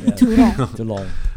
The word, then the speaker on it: Toulon: It brings back the main antagonist of André Toulon. Note the pronunciation. – Toulon.